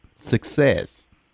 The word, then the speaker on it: Succezz: It's spelled SUCCEZZ, Success.